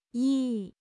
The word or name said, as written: 亦